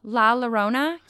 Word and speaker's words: La Llorona: The next one I have, I think I'm saying this right, La Llorona, I think. I can't, it's really hard to say. – La Llorona? – La Llorona, yes.